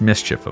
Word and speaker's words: Miss Chief: For Monkman's installation, he created a gallery-sized buffalo jump, with two bison standing atop a cliff beside a sculpture of Miss Chief, pronounced Mischief, of course, which is the artist's alter ego.